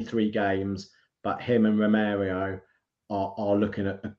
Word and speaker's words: Romero: Are, are you going to be pronouncing him Romario the whole season? I I I, I pronounce it Romero.